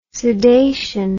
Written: sudation

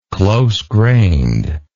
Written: close-grained

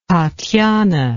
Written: tatiana

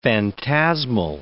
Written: phantasmal

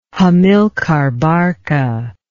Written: Hamilcar Barca

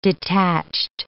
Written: detached

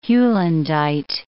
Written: heulandite

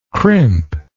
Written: crimpness

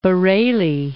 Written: Bareilly

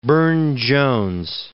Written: Burne-Jones

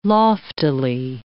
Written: loftily

